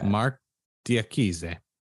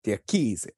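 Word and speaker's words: Diakiese: How do you say that? – Mark Diakize. – Diakize. I like that.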